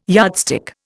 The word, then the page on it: yardstick: Indian